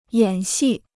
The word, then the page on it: 演戏: yǎn xì